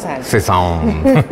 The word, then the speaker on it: Cézanne: Cézanne.